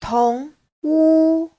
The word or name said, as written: tong2wu1